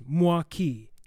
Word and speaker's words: Milwaukee: If you hear it, the true pronunciation is Mwa-Kee.